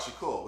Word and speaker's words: Shakur: Even me, I'm such a fan of Tupac Shakur, which rhymes with Agent Provocateur.